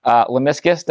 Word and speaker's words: lemniscus: But then the signal travels down and it goes to a piece called the nucleus of the lateral lemniscus. Did I pronounce that correctly? – Yeah.